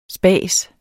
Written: spas

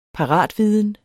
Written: paratviden

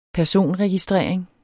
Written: personregistrering

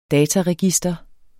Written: dataregister

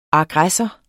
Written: aggressor

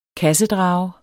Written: kassedrage

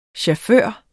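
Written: Chauffør

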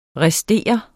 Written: restere

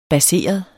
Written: baseret

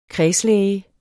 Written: kredslæge

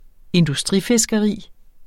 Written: industrifiskeri

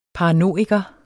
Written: paranoiker

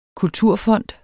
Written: kulturfond

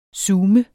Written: zoom

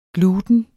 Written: gluten

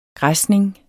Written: græsning

